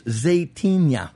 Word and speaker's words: Zaytinya: And I mispronounced it like Zaytinya. – It's Zaytinya.